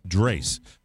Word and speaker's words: Drace: I'm sorry, not Drace. Drace. Rhymes with space.